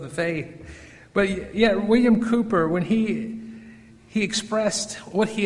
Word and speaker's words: Cowper: Most people pronounce it William Cowper because it's spelled C-O-W-P-E-R, but it's pronounced Cooper, William Cooper.